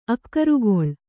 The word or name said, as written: ಆಕಾರಗೊಳ್ಳು